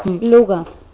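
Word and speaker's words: Louga: Luga.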